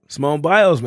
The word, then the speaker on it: Simone Biles: So, if, for those of you that don't know, he's dating Simone Biles from the I think that's how you say it. – Simone Biles, man.